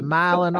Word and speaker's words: Milan: In Tennessee, where my wife is from, they have a city called, it's spelled Milan, like in Italy. That's Right. – not how they say it. They say Milan.